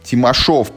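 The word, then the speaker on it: Тимашёв: Вот поддержите Олега, поддержите Юру Ямщикову, поддержите вот это вот молодое издательство, как оно называется, Тимашев Геймс, да, или Тимашов, пардон, с ударением на «ё».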